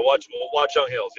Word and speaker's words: Watchung Hills: Now he's a coach in high school at Watchung Hills. Did I say that right? – Yeah, Watchung Hills, yep.